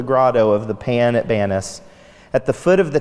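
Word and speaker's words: Banias: Indeed, welcome to Banis, the Arabic pronunciation for Pan. Arabic evidently has no P sound.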